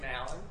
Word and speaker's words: Alan: First name, Alan, and I'll spell that, A-L-A-N. Nobody gets it right. Second name, Richter, R-I-C-H-T-E-R, and your address.